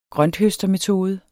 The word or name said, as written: grønthøstermetode